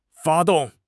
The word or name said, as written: 发动